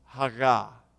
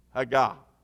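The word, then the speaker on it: haga (הגה): In the original Hebrew language, the word meditate would be roughly pronounced haga, H-A-G-A, haga. Remember that, haga.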